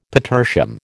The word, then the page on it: petersham: American English